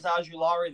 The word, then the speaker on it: Ojulari: Ojulari? – Oh, Azeez Ojulari, the edge from Georgia.